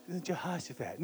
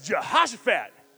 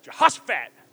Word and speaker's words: Jehoshaphat: No, not Jehoshaphat. No. You gotta say it like you're at least partially not white. It's like Jehoshaphat. Yeah, Jehoshaphat. Man, he just told the singers to sing.